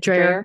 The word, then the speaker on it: Dreher: Rob Dreher, is that how you say it? – Dreher.